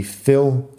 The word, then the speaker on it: fill: La única diferencia en pronunciación es que tiene la I larga. Feel. La diferencia entre feel y fill es que feel tiene la I larga y fill de llenar tiene la I corta.